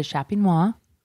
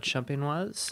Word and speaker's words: Champenois: I know there's the méthode Champenois. – I think it's Champenois, and I'm dropping the S to sound more fancy, but really I'm saying it incorrectly. – Champenois would be feminine.